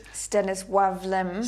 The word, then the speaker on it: Stanisław Lem: We're reviewing His Master's Voice by Stanislaw Lem, and you pronounce it Stanisław Lem. – Stanisław is a yeah. Stanisław Lem. He's Polish. – It's Polish. – It's got that little thing through it.